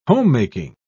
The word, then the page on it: homemaking: British English